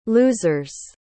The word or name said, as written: loosers